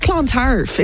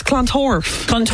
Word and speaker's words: Clontarf: It's not Clontarf, it's Clontorf.